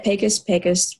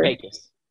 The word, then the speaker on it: Pecos: Pecos, whatever. – Pecos. – Pecos. Yeah. – Like P-E-Y, Cass.